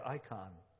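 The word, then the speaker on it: icon (εἰκών): And this word in the Greek, to become like Him, is literally the word, really the word, icon. That's how you say it in the Greek language.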